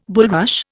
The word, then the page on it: bulrush: Indian